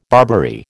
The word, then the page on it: Barbary: American English